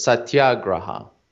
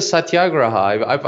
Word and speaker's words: satyagraha: Well, I'm not certain that this is how it's pronounced, but I'm pretty sure it's satyagraha. Because I think that's how Michael Nagler pronounces it.